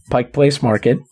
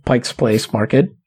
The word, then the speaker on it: Pike Place Market: and out by Pike Place Market, or as you pronounce it, if you want to piss off anybody from Seattle, Pike's Place Market.